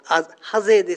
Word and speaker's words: 櫨: 黄櫨染っていう、黄色の木という字と、ハゼですね、ハゼ、ハゼ塚町のハゼです。